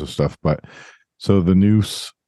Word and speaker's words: nous: I was just going to kind of read what noose is, or noose. N-O-U-S is how it's spelled.